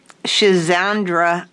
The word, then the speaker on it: Schizandra: And then there's this white Schizandra berry tea, which I'd never heard of at all. – You said that correctly.